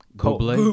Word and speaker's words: Bublé: Johnny Halliday, Ed Sheeran, Michael Bubble. – Bublé. – He said Bubble. – B-U-B-L-E? – Bublé. – Bublé. Okay. Yeah. They didn't have the accent on there.